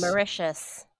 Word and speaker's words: Mauritius: How do you say Mauritius? – Mauritius.